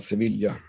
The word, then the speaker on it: Sevilla: Jag säger på svenska Sevilla. I Sverige uttalar vi... – För så har det alltid hetat.